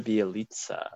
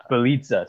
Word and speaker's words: Bjelica: Nemanja Bjelica. – Bjelica, sorry.